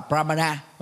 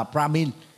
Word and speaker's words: Brahmin: Và khi Anh hóa đó thì ta gọi đó là Brahmin, chứ không đọc là Brahmana mà là Brahmin.